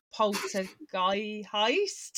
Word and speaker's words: Poltergeist: So I say, if I don't think about it, I say it as Poltergeist. And I just can't stop saying it like that. So I've been practicing. So someone told me to say it like, remember, it's like heist. So Poltergeist.